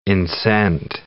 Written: incent

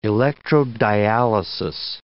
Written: electrodialysis

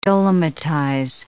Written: dolomitize

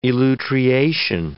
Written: elutriation

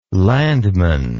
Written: landman

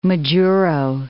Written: Majuro

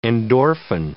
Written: endorphin

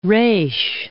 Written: reis

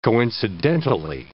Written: coincidentally

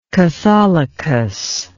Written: katholikos

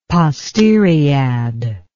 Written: posteriad

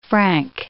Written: franc

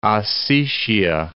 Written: Ossetia

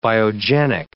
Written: biogenic

